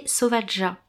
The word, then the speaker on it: Sauvaja: Donc à l'origine, le restaurant s'appelait Sauvaja, ce qui veut dire en espéranto sauvage. Donc ça a été choisi volontairement en espéranto, Sauvaja. Malheureusement, avec le temps, les gens ne prononcent pas forcément le ja, et le restaurant a changé de nom, il s'appelle Sauvaga désormais.